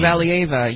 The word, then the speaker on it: Valieva: Kamila Valieva, is that how you pronounce her name? – Oh, Valieva, yeah.